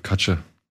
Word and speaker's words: Kutcher: oder du hast gerade Kutcher gesagt, spricht man ihn so aus? – Kutcher.